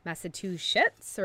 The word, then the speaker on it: Massachusetts: Massachusetts or sits? – Massachusetts.